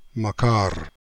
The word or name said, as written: Makar